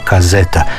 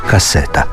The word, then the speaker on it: kaseta: Jezičnim stručnjacima i danas nije jasno kako se unatoč svemu pojavila kazeta, i jasno i glasno nam podcrtavaju, u hrvatskom jeziku piše se i govori kaseta.